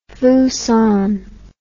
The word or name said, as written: fusan